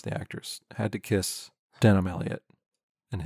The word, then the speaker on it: Denholm: No. No, you need to make it sound natural and Denholm. Fast.